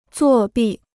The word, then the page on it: 作弊: zuò bì